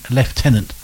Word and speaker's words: Lieutenant: And I'm pronouncing it properly, it's Lieutenant. – It is Lieutenant. – You do not rent a toilet.